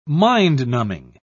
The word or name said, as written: mind-numbing